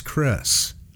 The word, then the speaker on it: Chris: Came up with the phrase, and Cree, most of my Chinese friends, and Mexican friends, Italian friends, can't pronounce Chris, so they call me Cree.